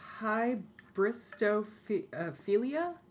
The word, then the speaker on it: hybristophilia: Bundy is one of the best known examples of hybristophilia. – Mm-hmm. Yeah. – Is that how you say it? Hybristophilia? – Yeah.